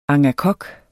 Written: angakok

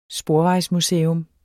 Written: sporvejsmuseum

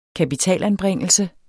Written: kapitalanbringelse

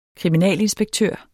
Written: kriminalinspektør

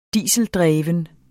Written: dieseldreven

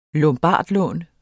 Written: lombardlån

